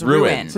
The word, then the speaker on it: ruined: It's two syllables. – Ruined.